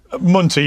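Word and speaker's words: Munther: Yes, I've said on several occasions, and here I'll echo your previous speaker, Montier, I guess his name is. Is that correct, his name? Mm. I don't want to mispronounce it. – Munter, yeah. I'm not sure quite how the local pronunciation is, yeah.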